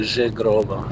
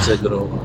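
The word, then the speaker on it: Zhegrova: Si pronuncia Zegrova, non Zegrova. Una Z seguita da un H nella lingua albanese si pronuncia sempre comunque J.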